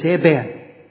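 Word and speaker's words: Eben (אבן): He says, would I be right in saying that the word stone, which is Eben, the word stone in Hebrew, starts with a quiescent letter or a silent letter. He says, that's right. That's absolutely right. So I could pronounce that Hebrew word stone and leave that letter out. You could. And what would I be left with? Would it be the word son? He says, you're right. Ben.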